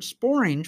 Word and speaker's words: sporange: According to Google, there is one word, sporange, which rhymes with orange.